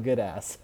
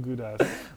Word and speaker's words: Gudas: Radko Goodass. no, it's Radko Gudas.